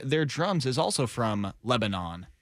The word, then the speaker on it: Lebanon: He was actually born in Lebanon. And then it is pronounced Lebanon.